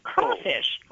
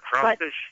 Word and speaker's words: crayfish: It's spelled crayfish, and I think most people in... crayfish territory pronounce it crawfish. – Yeah, crawfish.